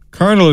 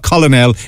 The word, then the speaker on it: colonel: Colonel is colonel, some people say. No, it's not colonel, it's colonel.